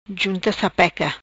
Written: Junta sapeca

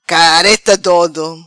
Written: Careta dodo

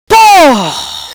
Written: Pô

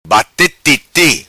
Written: Titi' Bate

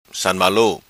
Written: San Ma Lu

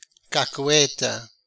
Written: Cacueta

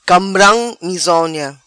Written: Cambram Mizona

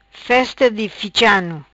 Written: Festa di Ficha Ano

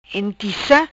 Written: Entica

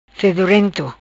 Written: fedorento

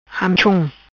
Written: Amechom

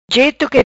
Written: Jeto qui

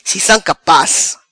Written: Si sam capaz

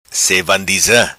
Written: Sevandiza

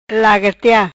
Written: Lagartia

